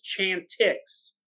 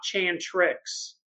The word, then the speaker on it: Chantix: I think it's Chantix, not Chantrix.